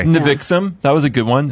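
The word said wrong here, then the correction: NXIVM: Is that how you pronounce that? – Nexium. – Nexium. – Yeah. It's spelled like that, though.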